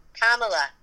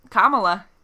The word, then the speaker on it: Kamala: Kamala. – Kamala. Yep, you said that right.